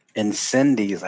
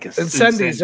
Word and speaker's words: Incendies: Also, I think Incendiaries, how do you pronounce it? – Incendies, I think. – Incendies, there you go.